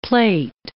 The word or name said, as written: plait